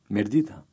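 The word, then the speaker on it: Mirëdita: Mirdita. – The speaker is going to repeat this expression part by part, starting from the end.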